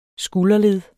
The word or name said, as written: skulderled